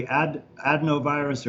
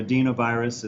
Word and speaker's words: adenovirus: So that's basically adenovirus or adenovirus, it's pronounced both ways, is another common type of virus that's associated with the common cold, just like coronaviruses are.